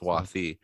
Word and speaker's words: Swathi: And I believe that's a pronounced, it's spelt Swati, but it's pronounced Swathi.